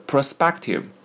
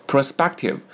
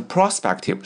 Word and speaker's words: prospective: The prospective inspector speculated on his prospects from his own perspective. The prospective inspector speculated on his prospects from his own perspective. 好，首先来看第二个单词 prospective. Prospective 重音在 SPE 这里啊，不要念成 prospective, 是 prospective 啊 SPE.